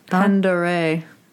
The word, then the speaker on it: Pend Oreille: Seattle City Light operates dams directly east of Mount Baker and in Pend Oreille County in eastern Washington. – Pend-Oreille. – How do you say it? – Pend-Oreille. – What did I say? Pend Oreille? – Mm-hmm.